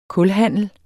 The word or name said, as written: kulhandel